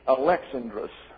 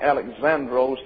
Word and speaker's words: Alexandros: That is spelled Alexandros. The accent is on the second syllable. Therefore, Alexandrus. Now, from this, we get the English name, Alexander. And we put the accent down here. But it's not Alexandros, it's Alexandrus, is the way it's pronounced in the Greek.